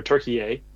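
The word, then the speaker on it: Türkiye: or Turkey-A, as the proper pronunciation.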